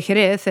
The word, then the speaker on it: Jerez: J-E-R-E-Z. So it's pronounced Jerez. You're ordering, can I get a vino de Jerez?